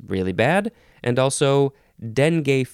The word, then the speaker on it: dengue: The next word is dengue or dengue. I've always heard it pronounced dengue, but I guess dengue is fine too. It's fine. This is spelled D-E-N-G-U-E, noun from 1828.